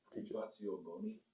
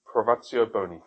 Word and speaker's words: privatio boni: Privatio, help me out here. – Privatio boni. – Privatio boni. Thank you. I didn't want to pronounce it wrongly.